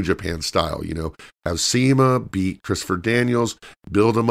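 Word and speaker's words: Cima: I think it's Shima, pronounced Shima, but it's C-I-M-A, who is a legend in Asia, but also beat Christopher Daniels at the last event.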